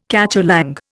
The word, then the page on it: cacholong: Indian